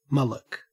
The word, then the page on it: mullock: Australian English